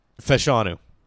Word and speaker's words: Fashanu: I don't know if we should get a an offensive lineman and like Joe Alt or I think it's Fashuna. Let me look that up before I fucking butcher it. Uh, Fashanu. Olumuyiwa Fashanu out of Penn State.